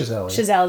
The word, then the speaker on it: Chazelle: Chazelle is not brilliant.